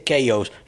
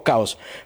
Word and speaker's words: Chaos: Pero bueno, ya estamos en este baile y vamos a sumarnos a las celebraciones de los 20 años de Chaos and Creation in the Backyard. Chaos, ya me dijeron, se dice Chaos. Nosotros al Chaos de ustedes le decimos Caos, pero en inglés se dice Chaos, es lo único que voy a pronunciar bien.